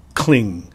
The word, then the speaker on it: cling: Now, watch out for the pronunciation of cling. This is actually not a very easy word, and it needs to be clear because it sounds like other words. For example, clean. So, this is ing, C-L-I-N-G, and the ing is much like a pinyin ing, so it's cling, cling.